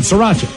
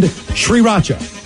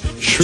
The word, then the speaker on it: Sriracha: Again, the correct pronunciations, Worcestershire and Sriracha. – Sriracha.